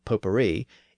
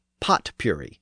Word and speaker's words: potpourri: It's pronounced in the French manner, potpourri, even though it looks like pot puree, because it has that word pot in it.